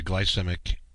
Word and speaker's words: glycemic: That's what the glycemic, did I say that right? – Glycemic, yep. So the glycemic index.